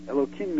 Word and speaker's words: Elohim: comes out to the same numerical value as Elohim. I'm not, you know, the end of that The third syllable of that is him. So there are three syllables, air, low, and the last syllable is him.